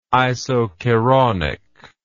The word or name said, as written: isokeraunic